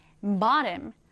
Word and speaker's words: bottom: bottom. 강세는 앞에 둡니다. bottom. 이렇게 발음을 해요. 자, 오른쪽에 같이 한번 써보도록 하겠습니다. B-O-T-T-O-M.